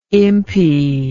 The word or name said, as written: impi